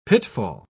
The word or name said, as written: pitfall